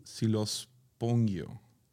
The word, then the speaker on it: Xylospongio: Así se escribe. X Y L O S P O N G I O. Si, los, tengo. – Si los pongo.